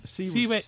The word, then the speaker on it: Siewert: S-I-E-W-E-R-T is Seaweed. They pronounce it, don't they?